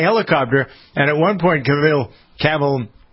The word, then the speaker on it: Cavill: Cavill? – Cavill. – Cavill.